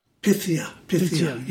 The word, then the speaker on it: Pythia: I think in the next track, which is called Pythia, do I pronounce this right? Pythia? – Pythia, yeah.